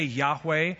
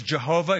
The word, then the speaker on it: Yahweh: All we have left from the ancient text is a four-lettered word called a tetragrammaton, four consonants in Hebrew, that could be pronounced a number of ways. Typically, I say Yahweh. The old pronunciation was Jehovah. It could be Yahovah, Yahowah.